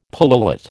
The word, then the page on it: pullulate: American English